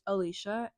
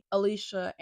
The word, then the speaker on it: Alisha: As I said, my name is Alicia, and I will tell you how to spell it, because apparently some people struggle with it.